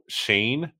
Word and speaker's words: Schoen: The name thing is throwing me off because apparently it's Joe Shane, but okay, there's an O-E in his last name. It's spelled S-C-H-O-E-N. That's how it's spelled. Yeah. The Shane, right.